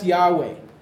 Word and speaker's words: Yahweh: That's Yahweh. That's how we pronounce it.